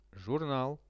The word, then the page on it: zhurnal: Russian